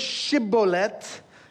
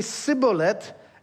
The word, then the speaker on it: Shibboleth: The word is Shibboleth. Ephraim doesn't have the sh sound, so they say Sibboleth.